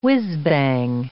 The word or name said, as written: whizzbang